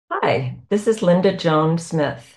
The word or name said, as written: Linda Joan Smith